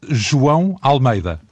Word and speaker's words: João Almeida: Juan Almeida, ça s'écrit J-O-A-O Almeida. Et on prononce João Almeida.